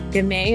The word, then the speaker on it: Gamay: Gamay is how it's pronounced.